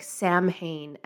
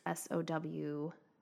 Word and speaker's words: Samhain: It looks like Samhain, Samhain, but it's pronounced Sowhen, Samhain, which is the Celtic, in the Celtic origin, name for this celebration that we have around October 31st.